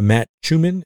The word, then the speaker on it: Matt Schumann: Um, he's having some great conversations with uh, someone called The Gospel in the Garden and um, uh, Matt Schumann. Is that, did I say it right? Yep.